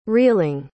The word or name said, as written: reeling